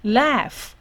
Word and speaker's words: laugh: 可是当 gh 它放在字尾的时候呢，它念 f 就 f 的发音，念 f 的发音哦，所以这个是 laugh laugh，OK，所以它一种。